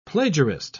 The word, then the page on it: plagiarist: British English